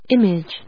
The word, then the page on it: image: im・age; ímɪdʒ